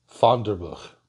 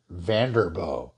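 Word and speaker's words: Vanderboegh: Now, Vonderburg always pronounced it Vanderbo. So that's the pronunciation I'm going to adopt.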